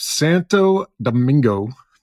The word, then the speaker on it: Santo Domingo: Born in the city of Santo, Domingo, Dominican Republic. I said that right? – That's right.